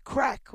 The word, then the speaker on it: craic: And so the combination of the charm of what Doolin itself looks like, great food, as they would say in Ireland, great craic, which has nothing to do with drugs, people. It's spelled C R A I C, and it means great fun.